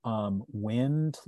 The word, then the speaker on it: wind: but our word wind, like the wind is blowing, didn't used to be pronounced wind because it was always rhymed with words like kind and mind and find.